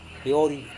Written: piori

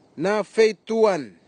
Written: nafetuwan

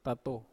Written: tatou

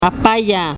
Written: apayaa

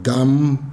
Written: dam

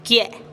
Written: kie'

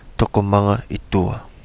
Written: Togo Manga I Dua

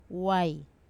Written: wai

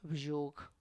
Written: bzhug

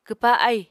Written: kwpa ahì

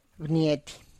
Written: bniety

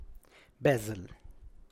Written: biaz

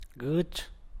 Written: benduy

